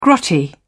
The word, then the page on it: grotty: British English